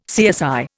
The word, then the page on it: CSI: Indian